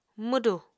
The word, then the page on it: mwdù: Apatani